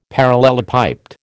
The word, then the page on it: parallelepiped: American English